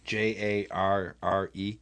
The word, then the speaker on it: Jarre: J A R R E. – That's how you pronounce it. J A R R E. You hear that, guys? – J-A-R-R-E.